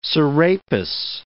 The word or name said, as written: Serapis